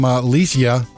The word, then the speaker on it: Lesia: Also from Facebook, I got this one from Leisha, L-E-S-I-A.